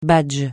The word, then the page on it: badge: francais